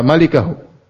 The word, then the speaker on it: malikahu: Kita bilang salah bacanya, bukan mulkahu. Kalau diartikan juga kecuali kerajaannya, berarti enggak ada yang hancur. Semua ini kerajaan Allah, bukan? Semua ini milik Allah, bukan? Kita ini milik Allah, bukan? Kalau gitu enggak ada yang hancur dong, enggak ada yang binasa. Paham? Kalau kita artikan kecuali kepemilikan Allah, maka semua kita ini milik siapa? Allah. Kalau gitu enggak ada yang sirna. Yang benar bacanya illa mulkahu. Yang benar bacanya malikahu. Bacanya illa malikahu, yaitu pemiliknya, pemilik wajah.